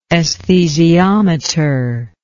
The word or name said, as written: esthesiometer